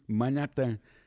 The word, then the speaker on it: Manhattan: Chama-se mesmo assim, Manhattan. – Pronunciado à brasileira.